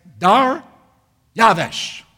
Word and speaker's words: Dariavesh: And so I looked it up and found the right pronunciation in Englishman's Hebrew, Chaldean, Greek concordance of the scriptures, and it's Dariavesh.